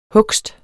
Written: hugst

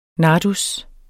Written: nardus

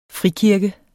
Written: frikirke

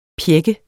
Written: pjække

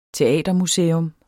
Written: teatermuseum

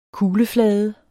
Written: kugleflade

